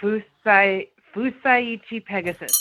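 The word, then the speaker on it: Fusaichi Pegasus: Yeah, Fuseichi Pegasus. Did I say it right? – And you were correct. That is right.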